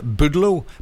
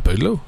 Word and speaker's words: Boudleaux: Felice Bryant with her husband, Boudleau. B-O-U-D-L-E-A-U-X. How would you pronounce that? – What? – B-O-U-D. – Yeah. – L-E-A-U-X. – Yeah, Boudleau. – Boudleau? Boudleau? – Yeah.